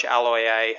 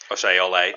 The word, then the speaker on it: Aloiai: Uh, my commiserations to Josh Aloiai, who had his name mispronounced. – Oshay Ole.